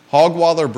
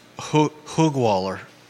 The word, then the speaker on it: Hogwaller: Hogwaller Brewing is open today on High Street. – With an umlaut like that, it should be pronounced Hoogwaller.